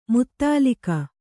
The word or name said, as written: ಮೂದಲಿಕೆ